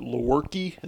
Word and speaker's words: Lewerke: I think uh, is it Lewerke? Is that how it's pronounced? Yeah.